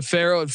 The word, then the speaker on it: Faurot: So tell me about your experiences to Ferret Field. The T is silent. I keep making that mistake.